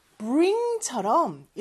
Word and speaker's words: bring: 그리고 참고로요, bring처럼 이렇게 맨 앞에 두 개 이상의 자음이 붙어 있는 경우는 맨 뒤에 자음이 가장 강하게 들려야 돼요. So bring 같은 경우는 B-R-I-N-G잖아요. 그래서 R 발음이 가장 크게 들려야 합니다. Ring, ring 이렇게 연습을 한 다음에 앞에 B를 살짝 넣는 연습을 해주면 되는 거죠. Bring, bring 자, B가 있긴 한데 R 발음이 가장 크게 들리게 이렇게 해주시면 돼요.